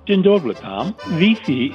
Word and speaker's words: Wi-Fi: The letter I in Polish gives us an E sound. So that's why Wi-Fi is pronounced Wi-Fi.